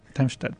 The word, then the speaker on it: Darmstadt: ¿Lo pronuncié bien? Darmstadt, en Alemania. – Darmstadt, perdón, sí, en Alemania, y con algunas otras, digamos, sucursales, pero es donde lo manejan todo lo de la nave.